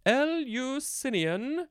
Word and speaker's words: Eleusinian: Now we have the word Eleusinian. Eleusinian. Yeah. I think that's how you say it. Capital E-L-E-U-S-I-N-I-A-N. Nope, I said it wrong. Eleusinian. I don't know how I missed the emphasis there.